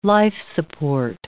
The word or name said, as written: life-support